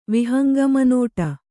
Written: ವಿಹಂಗಮನೋಟ

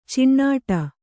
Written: ಚಿಣ್ಣಾಟ

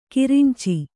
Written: ಕರಂಚು

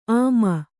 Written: ಆಮ

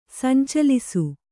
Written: ಸಂಚಲಿಸು

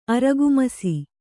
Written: ಅರಗುಮಸಿ

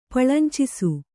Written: ಪಳಂಚಿಸು

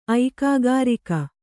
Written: ಐಕಾಗಾರಿಕ

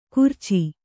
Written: ಗುರ್ಚಿ

